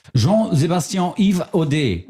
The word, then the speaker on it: Jean-Sébastien Yves Audet: Jean-Sébastien Yves Audet, der wird wirklich französisch ausgesprochen, denn der kommt aus Kanada und da kann man das.